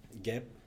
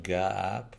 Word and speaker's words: GAAP: GAAP, the U.S. Generally Accepted Accounting Principles. – Oh, you pronounce that GAAP? It's not GAAP, because it's G-A-A-P. – Yeah, it's G-A-A-P. – But people say GAAP.